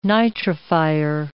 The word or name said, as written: nitrifier